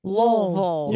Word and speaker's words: Louisville: You have to say it like this. – Louisville. – Yeah, Louisville. It's almost just one sound. Louisville.